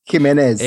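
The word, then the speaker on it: Jiménez: No, J is like an H. Jimenez.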